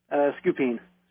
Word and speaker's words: Scupien: S-C-U-P-I-E-N. How would you pronounce that, Sarah? – Supian? – That's exactly what I said. – Well, that's what most people get. – What is it? – Scoopin.